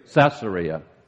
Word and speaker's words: Caesarea: Some Bible students believe that when he was really in prison was when he was in Caesarea. Is that how you say it? – Caesarea.